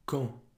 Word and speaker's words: quand: Quand. – So that's Q-U-A-N-D, but the D is silent. Quand.